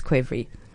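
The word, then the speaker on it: qvevri: But in English, we spell it with a Q and it's pronounced quevry.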